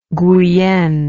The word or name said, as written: Guienne